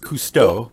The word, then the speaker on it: Cousteau: Constitu? – It's pronounced Cousteau, but yes.